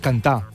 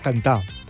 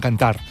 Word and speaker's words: cantar: En català central diem cantar. Ell va cantar. – S'escriu amb R final, però no es pronuncia aquesta R. – Sí, queda muda. Queda emmudida. És aquest fenomen d'emmudiment. D'acord? Que aquesta R sí que es pronuncia, per exemple, en valencià.